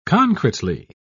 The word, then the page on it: concretely: British English